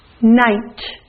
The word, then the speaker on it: night: Next, night. Night. I-G-H makes the I sound, but the G-H is silent, so you hear night.